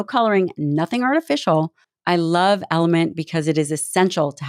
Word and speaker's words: LMNT: Element, spelled L-M-N-T, but pronounced Element, is an incredible electrolyte drink mix that comes in cute little packets that you can bring with you everywhere.